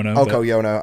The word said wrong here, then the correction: Yoko Ono: Yoko Ono. – Yeah, Yoko Ono.